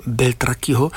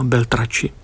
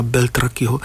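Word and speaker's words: Beltracchi: května se v Obecním domě otevře nová výstava kontroverzního německého malíře, vlastně známého spíš dlouhodobě jako slavného padělatele umění Wolfganga Beltrakiho. Snad ho vyslovuji dobře, protože tam ta německá výslovnost, nikoli ta italská Beltrači, tak tedy Wolfganga Beltrakiho,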